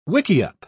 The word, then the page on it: wickiup: British English